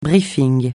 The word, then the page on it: briefing: francais